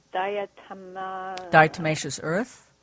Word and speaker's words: diatomaceous earth: And I've seen, I don't even know how to pronounce it, but this diatomaceous earth.